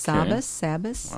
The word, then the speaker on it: Sabbas: S-A-B-B-A-S. Okay. Sabas.